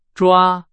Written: 抓

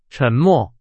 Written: 沉默

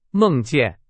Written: 梦见

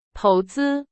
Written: 投资

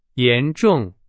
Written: 严重

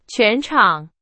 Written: 全场

